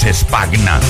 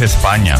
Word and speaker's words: España: Sí, sí, aquí la llamábamos Spagna, pero no, es España, se pronuncia así.